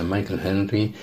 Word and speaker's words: Michael Henry: Michael Henry, me lo aclaró y todo, que era con pronunciación inglesa, Michael Henry, además el hijo de Juan Henry, antiguo miembro de la cofradía, un abrazo Michael.